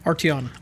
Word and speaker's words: Arteon: Is it Arteon or Arteon? – Arteon.